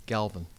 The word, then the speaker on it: Galvin: Galvin.